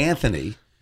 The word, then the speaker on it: Anthony: I had a hard time with it because I wanted to say Anthony, and I had to. – It's a silent H.